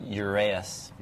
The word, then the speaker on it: Uraeus: Uraeus.